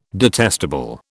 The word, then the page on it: detestable: British English